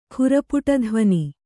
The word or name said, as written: ಖುರಪುಟಧ್ವನಿ